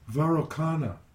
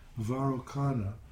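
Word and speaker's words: Vairocana: Varokana is the correct name. – Yeah. – Varokana is the correct name of the Buddha that's in the center of the mandala.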